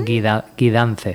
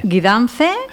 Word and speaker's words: Guidance: Guidance. – Guidance. – G-U-I.